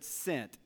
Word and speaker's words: scent: Which letter is silent, think about this, in the word sent, S-C-E-N-T? Is it the S or the C?